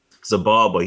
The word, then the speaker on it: Xibalba: right, and when he looks when he looks scared of uh uh, uh, uh Ex-Balba, how do you say his name? Uh, Xibalba. He actually looks scared.